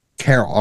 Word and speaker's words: Cairo: You know, I've heard controversial names, Cairo. – It's Carol.